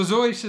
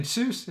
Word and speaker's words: Seuss: So Zoyce is the way the family pronounced the name. It's the way Teddy pronounced the name. But Americans, being Americans, decided it's Seuss, and Seuss it has been and will likely always be.